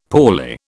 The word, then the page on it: poorly: British English